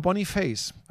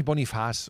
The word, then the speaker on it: Boniface: ähm, der heißt Victor Boniface. Aber Dann, was wollte? Keine Ahnung, wahrscheinlich Boniface oder so. Jetzt pass auf. Äh, ist aber kein Franzose.